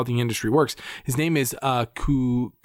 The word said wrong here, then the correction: Kumail: Nanjiani. Oh, wow. I butchered his name. – Don't even. – Incredible actor. Kumail Nanjiani. Whatever.